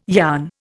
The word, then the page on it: yarn: Indian